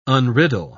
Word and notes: unriddle: British English

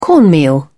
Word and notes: cornmeal: British English

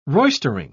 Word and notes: roistering: British English

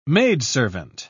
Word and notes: maidservant: British English

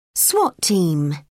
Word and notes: swat team: British English

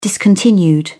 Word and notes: discontinued: British English

